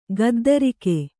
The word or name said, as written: ಗದ್ದರಿಕೆ